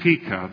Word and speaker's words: Pekah: Then Pekah, this I understand. Break the name down to two syllables and you got a deal.